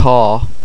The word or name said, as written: tá